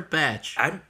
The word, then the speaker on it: Cumberbatch: Is it botch or batch? – I always say batch because okay, that's botch. How it's spelled. – I'm a botch. – Benedict Cumberbatch, batch, botch, tomato, potato.